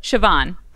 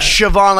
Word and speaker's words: Siobhán: Siobhan. – Siobhan. – Yep. – Okay. – Like Siobhan, like S-H-A-V, as in Victor, yeah. A-N.